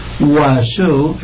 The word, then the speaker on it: oiseau: Oiseau, pájaro, hablando de murciélagos, oiseau es pájaro, O-I-S-E-A-U, se pronuncia oiseau.